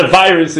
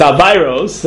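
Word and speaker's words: aveiros: It's not a virus, it's aviros. That's how they pronounce the word aviros.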